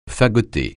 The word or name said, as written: fagoter